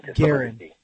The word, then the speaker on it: Guerin: Guerin, and I hope I'm pronouncing that right. – Guerin.